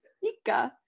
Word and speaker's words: fika: It's fika.